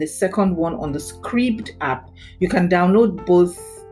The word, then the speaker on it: Scribd: I highly recommend the Scribd app. It's spelled S-C-R-I-B-D, Scribd.